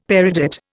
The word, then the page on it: peridot: Indian